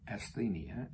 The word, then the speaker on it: asthenia: I think I can pronounce this one, asthenia, A-S-T-H-E-N-I-A, asthenia.